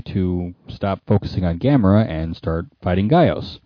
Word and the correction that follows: Gyaos: So, Gauss. – Sorry. – Okay. It looks like it should be pronounced. – It's Gyaos for the record.